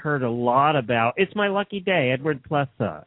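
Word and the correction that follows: Plesa: Eddie Pleza. It's pronounced Pleza.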